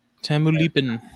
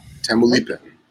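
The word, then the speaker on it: Tamaulipan: So this is Crotalus marulus, the Tamaluyapan, I always say that wrong. Tamaluyapan rock rattlesnake. – Tamulipin. – Tamulipin.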